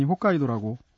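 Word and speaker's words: 홋카이도 (北海道): 북해도는 한자 발음이고 일본어에서는 당연히 홋카이도라고 발음을 하겠죠.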